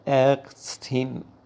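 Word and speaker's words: Ärztin: این لغت به صورت ارستین تلفظ میشه دوستان که با حالت مذکرش متفاوت هستش.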